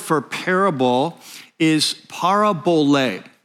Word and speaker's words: parabole: Parabole with the accent on the last syllable. That's why I put it up there on the number and the parentheses. So parabole, capital L, capital E, that indicates the accent.